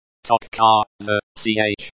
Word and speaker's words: точка: Точка, the ch, is similar to the ch in the English word, chair.